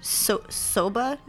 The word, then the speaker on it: soba: And soba noodles? Am I saying that right? S-O-B-A. – Yep.